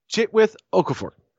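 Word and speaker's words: Chiwetel Ejiofor: Let me pronounce the actor's name. Chitwith Okafor. He is the actor who plays Baron Mordo.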